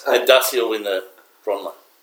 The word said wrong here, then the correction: Brownlow: Brownlow. – Brownlow. Who's Bronlow? Anyway, Axel, yours?